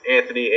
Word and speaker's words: Anthony: I know the, the, my last name's a little tricky to spell though, so uh, my direct URL is, is Anthony A N T H O N Y, and Mychal is spelled M Y C H A L.